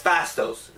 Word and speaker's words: Phastos: Phastos.